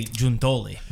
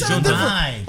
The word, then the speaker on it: Giuntoli: He said that from the region that Giuntoli is from, they say Giuntoli.